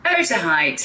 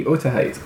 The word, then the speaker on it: Otaheite: Otaheite. – Thank you. – Is that just Tahiti backwards? – No, it's spelled Otaheite. Otaheite. So they spent 5 months there collecting and preparing 1,015 breadfruit plants to be transported.